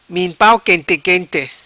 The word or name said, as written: Min pau quente quente